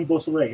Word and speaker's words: Beausoleil: Beausoleil. Am I pronouncing that correctly? – I know Bobby Beausoleil. Yeah.